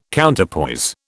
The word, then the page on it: Counterpoise: British English